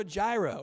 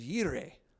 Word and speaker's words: Jireh: He's Jehovah Jireh, or maybe the more correct pronunciation is Yireh, I've heard lately.